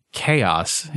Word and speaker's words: Chaos: But there are these little critters in Sonic, in the later Sonic games, called, I guess they're called Chows. – Yeah. – But it's fucking Chaos. It's, like, K-O's. Why do they pronounce it Chows?